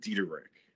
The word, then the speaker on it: Diederich: Well, there is multiple ways, of course, as you now know, to say Diederich. So, you know, Diederich, Diederich. In all my years of life, I've worked with all of them. But my family clan, per se, you know, we pronounce it Diederich. – Okay, like the American name that the American way, the pronouncing.